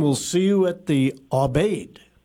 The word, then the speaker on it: Abade: And pronounce it again. – Abade or Abade? – Abade. Okay, there we go. – Yes, Abade. Yes. Mm-hmm. Abade, like a serenade, but it's an Abade.